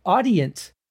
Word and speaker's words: Audient: I think it's audient is the way that you pronounce it. A U D I E N T.